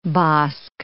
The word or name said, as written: bosque